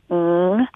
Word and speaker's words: Ng: I lived in Hong Kong before, and the actual pronunciation without the tones is ng, as if you're saying the end of a word that's NG. But